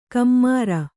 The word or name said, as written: ಕಮ್ಮಾರ